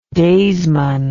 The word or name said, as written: daysman